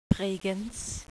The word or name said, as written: Bregenz